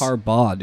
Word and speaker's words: Harbaugh: Harbaugh, dude. You keep saying Bog. You don't pronounce the H. – Harbaugh. – Or the G at the end.